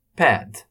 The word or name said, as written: pad